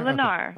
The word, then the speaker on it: Lennar: All right, so tell us about this relationship between Lennar Corporation. Is that it? Am I pronouncing that right? – Yeah, Lennar. Okay.